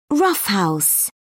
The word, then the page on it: roughhouse: British English